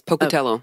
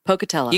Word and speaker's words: Pocatello: Did I say it right? – Yes, Pocatello. Oh, Pocatello.